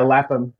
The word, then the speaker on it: Lapham: Is that how you pronounce his last name? Or Lampum? Like that. – Yeah. Lapham.